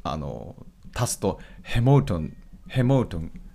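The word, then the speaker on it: Hamilton: ハミルトンさんですねってなっちゃうけど発音的にはやっぱヘモートンヘモートンって言った方が通じる、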